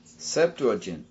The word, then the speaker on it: Septuagint: And in the Greek translation, in Septuagint, how do you say that? Or Septuagint? – Septuagint. – Septuagint. Septuagint. Okay, thank you.